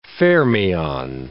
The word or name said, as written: fermion